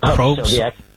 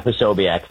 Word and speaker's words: Posobiec: And the Jack, how the hell do you pronounce that guy's name? Probst? – Sobiec. – How do you pronounce it? – Yeah, Posobiec.